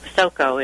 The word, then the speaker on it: Basoco: Basoco is my last name, and you did it right.